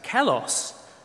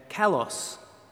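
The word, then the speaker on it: kalos (καλός): And then there's that word at the end there that's pronounced kalos. Apparently the emphasis is on the O, that little thing there, kalos.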